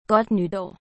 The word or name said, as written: godt nytar